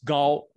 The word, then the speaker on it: Gao: Now, just to say, the correct way to say it in Mandarin Chinese is not even a K sound. It's a G sound. It's Gao. Gao is how you say the last name instead of Cao. – Really? – Yes, that is the correct pronunciation.